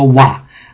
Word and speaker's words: Vav: Lior is saying, pronounce the Vav as a W regardless of one's ethnic background or Masoret. Yes, Lior, always as a W. There is actually no V sound in original Hebrew. It's always a Wa. And I don't care if you come from Eastern Europe or Eastern Mars, it's still pronounced as a Wa.